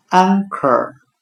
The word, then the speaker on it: anchor: 我们用谐音法来记忆这个单词 anchor，它的发音很像汉语的岸可，岸是海岸的岸，可可以的可。